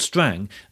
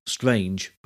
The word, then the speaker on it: Strange: Lord Strang, that spelt strange but pronounced Strang,